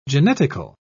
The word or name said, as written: genetical